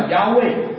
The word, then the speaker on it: Yahweh: 你知道这个神这个字在这个希伯来文里面是 YHWH 四个子音哈，希伯来文里面没有母音的，发音叫 Yahweh，我们中文翻成耶和华。